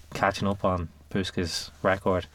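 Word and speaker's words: Puskás: Puskas Award.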